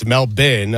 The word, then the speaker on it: Melbourne: Well, I thought it was Melbourne when we started today, but it's Melbourne. Okay. And that is courtesy of Noah Eagle. – Yeah, so Melbourne. The Australian pronunciation, is Melbourne.